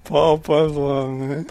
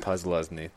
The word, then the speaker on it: Posluszny: Paul Posluszny. – Sorry, I can't say his name. – Paul Posluszny. – Posluszny, thank you.